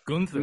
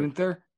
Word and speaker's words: Gunther: I appreciate you uh, saying his name correctly. As Gunther. Gunther. – Thank you. Apparently, Ted doesn't understand that it, you have to have a goon and then a thur.